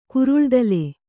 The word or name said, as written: ಕುರುಳ್ದಲೆ